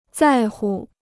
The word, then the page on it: 在乎: zài hu